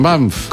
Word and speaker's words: Banff: Así es, en Banff. – ¿Cómo se escribe? Banff. – B-A-N-F-F, dos Fs, Banff.